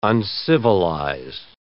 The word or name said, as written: uncivilized